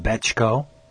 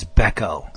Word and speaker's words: Bechko: It's not Bechko. – Becco. – It's Becco.